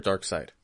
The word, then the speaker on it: Darkseid: Darkseid or Darkseid? – Side. – I think it's side. – It's but it's spelled seed, right? – Yeah.